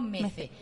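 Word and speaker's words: Mezze: Bueno, nosotras le decimos Metze porque lo leemos en el idioma que hablamos, entonces lo pronunciamos Metze.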